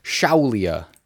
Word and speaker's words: Shaolia: So this game is called Shaolia. – Shaolia. – Or Shaolia. – How do you spell that? – S-H-A-O-L-I-A. – Oh, okay. – Shaolia. – Like a Shaolin monk.